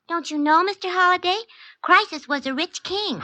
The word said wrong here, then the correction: Croesus: Croesus, not Crisis, was a rich king.